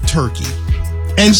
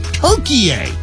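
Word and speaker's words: Türkiye: Forever we called it Turkey, and suddenly, through the World Cup, we're calling it Turkey A. Now, I know that's how they pronounce it in Turkish, but in English, we say Turkey.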